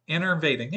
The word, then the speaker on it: enervating: Innervating, yeah, that's how you pronounce it.